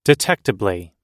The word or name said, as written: detectably